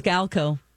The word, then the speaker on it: Maniscalco: Maniscalco. Is that how you pronounce Maniscalco.